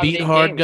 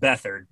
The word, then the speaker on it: Beathard: They still have that beat hard guy. – I believe it's pronounced Beathard, but yeah, I always say beat hard because that's how it's spelled.